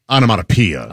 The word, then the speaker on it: onomatopoeia: Onomatopoeia. It's spelled poeia, but it's pia.